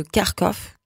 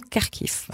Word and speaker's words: Kharkiv: et on a imposé rendre les noms des villes avec une prononciation ukrainienne. Et donc, et c'est comme ça que Kharkov, qui est à l'origine une ville très pro-russe, est devenu Kharkiv.